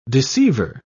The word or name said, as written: deceiver